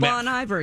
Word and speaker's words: Bon Iver: Um, and that is the one with Boney Vare. – Oh, um, Bon Iver, yeah.